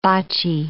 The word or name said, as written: bocci